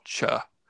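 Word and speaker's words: Chöd: The idea is, in Buddhist mythology, it is developed, it's based on an old Tibetan tantric practice called cha, which is spelled C-H-O-D with an umlaut over the O.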